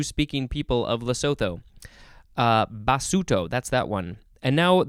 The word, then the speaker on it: Basuto: Now we have basuto. I think it's that's how it's pronounced. It is with a capital B A S U T O.